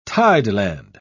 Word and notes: tideland: British English

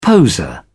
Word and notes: poseur: British English

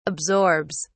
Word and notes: absorbs: British English

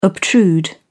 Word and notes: obtrude: British English

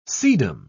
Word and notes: sedum: British English

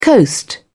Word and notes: coast: British English

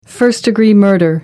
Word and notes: first degree: American English

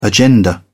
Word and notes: agenda: British English

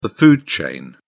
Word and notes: food chain: British English

